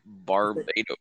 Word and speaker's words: Barbados: Barbados.